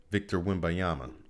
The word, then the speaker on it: Wembanyama: Um, Victor Wembanyama. I'm, I'm I know I'm saying it right. I've been practicing that.